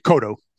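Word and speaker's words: Coto: By 1990, you and your writing partner on it, Manny Cotto. Am I saying that right? Cotto? Cotto? – Man, Kodo. – Cotto. I'm sorry.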